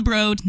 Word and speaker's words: Broad: It's called the Broad, not the Broad. It's spelled like Broad, but it's Broad apparently.